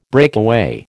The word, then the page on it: breakaway: American English